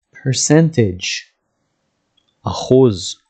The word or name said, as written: percentage